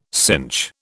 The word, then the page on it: synch: British English